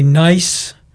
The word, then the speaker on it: Kneiss: It's Mary Ann, I'm going to say Nice, but it's spelled K-N-E-I-S-S.